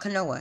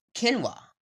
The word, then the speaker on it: quinoa: Some people call it quinoa canoa. Hey, tomato, tomato, potato, potato. But it's pronounced quinoa. Very fancy. The K is just invisible.